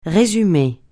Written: résumé

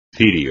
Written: cirio

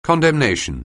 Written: condemnation

